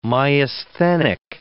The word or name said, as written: myasthenic